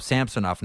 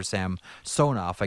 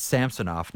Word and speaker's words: Samsonov: Although Ilya Samsonov, I guess he's calling himself Samsonov now. It's no longer Samsonov. I guess when you cross a border, you don't come up on the second syllable in Russian anymore. But I digress. It's Samsonov now.